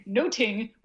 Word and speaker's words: nothing: There's like three puns in there, and we may maybe only get two of them now because we don't pronounce nothing noting, which is apparently the way they used to pronounce it, something much more like a noting.